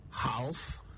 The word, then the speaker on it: half: H-A-L-F. Did you say half? Well, it's actually half. The L is silent. The same rule applies to the plural, halves, and the verb, halve. All of them are pronounced with a silent L. Half, not half. Half. It comes from an old Gothic word, hab, spelled H-A-L-B, also pronounced with a silent L.